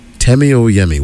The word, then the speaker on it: Temi Oyemi: Temi Oyemi. Welcome to Miami. It rhymes.